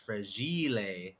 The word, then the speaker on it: fragile: Actually, it's actually an Italian word, and it's pronounced fragile.